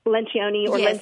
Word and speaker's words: Lencioni: Lencioni.